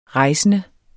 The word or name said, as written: rejsende